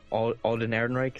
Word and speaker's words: Alden Ehrenreich: Thought the Alden Ehrenreich, is that how you say his name? – Yes, sir.